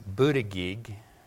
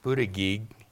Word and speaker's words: Buttigieg: You can Google his name and try and figure out how to pronounce it. It's Pete, and the way I got it was Buttigieg. Buttigieg.